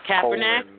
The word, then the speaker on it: Kaepernick: Kaepernick? – Colin Kaepernick.